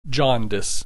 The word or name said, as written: jaundice